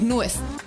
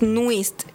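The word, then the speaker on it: NU'EST: Saludo a mi amiga Valentina, que no sabía que me estaba viendo y pronuncié mal Nuist. – Es Nuest. – No es Nuest, es Nuist. – Nuist. – Y me dijo, amiga, es Nuist, y yo...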